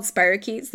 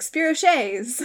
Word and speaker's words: spirochetes: They're called spirochetes. They're bacteria that have a little spiral shape to them. The most known one is Borrelia burgdorferi that is responsible for Lyme disease. Mm-hmm. But one of my students who had only ever read them and never actually like heard the name pronounced before was like, spirochets. And I was like, I love that. I'm going to call spirochetes spirochets from now on.